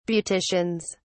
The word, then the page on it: beauticians: British English